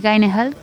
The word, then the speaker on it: Gyllenhaal: Otro drama que es un remake se llama La profesora de parvulario, se estrena ahora, la protagonista es Maggie Gyllenhaal,